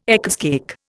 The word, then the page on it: Eccles cake: Indian